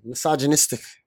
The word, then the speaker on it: Misogynistic: Misogynistic. Google it. Is that how you say it? – Yeah, that's how you say it.